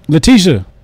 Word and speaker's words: Letitia: Letitia. Put some respect on that black woman name. – No, you know, man, you know, have to fact check this, man. Come on, it's L-E-T-I-T-I-A.